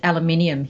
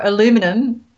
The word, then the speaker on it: aluminium: We actually pronounce it aluminium here. – Aluminium. – Aluminium. Or aluminum. It's probably, anyway.